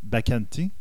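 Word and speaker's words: Baccarin: Alan Tudyk et euh, Morena euh, Bacanti. Oui. C'est comme ça ça se prononce.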